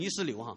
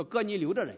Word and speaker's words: 哥尼流: 还有使徒行传第 10章有一个叫哥尼流的人，我经常把他读成尼斯流哈，叫哥尼流的人，那圣经上说他是一个敬畏神的人，所以神就差遣彼得来把福音传给他。